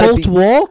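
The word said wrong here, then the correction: Colter: No, it's Coulter, C-O-L. – Coulter. – C-O-L-T-E-R. – Coulter. – Coulter. – Yeah. – Wall. W-A-L-L. – Wall. Spelled like Wall.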